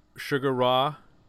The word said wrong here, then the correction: Sigur Rós: You mean Sigur Rós? – Sigur Rós.